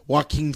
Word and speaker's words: Joaquin: Joaquin. Joaquin Phoenix, thank you.